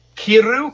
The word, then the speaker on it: kiru: And then in Japanese, like the English word kill is pronounced kiru, K-I-R-U. But the Japanese word K-I-R-U, kiru, means to cut.